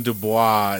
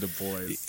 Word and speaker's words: Du Bois: I called him Du Bois instead of Du Bois.